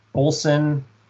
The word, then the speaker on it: Ulsan: Park has been with Lotte Chemical since 1991 and is currently senior vice president with responsibilities for production at Lotte Chemical's Daesan, Ulsan, and Yeosu, South Korea complexes. My Korean's a little rusty. Hopefully I pronounced those cities correctly. – I think you did pretty well.